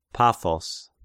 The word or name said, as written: Paphos